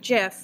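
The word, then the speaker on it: GIF: But really, Abby only, pronounces it the correct way. She pronounces it Jiff.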